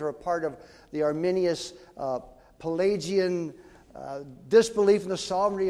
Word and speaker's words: Arminius: And they spent 12 years in Holland, and things were going smoothly until a man named Arminius, I've got that pronunciation wrong, let me get his name here, Arminius, thank you, Arminius began to teach a different gospel.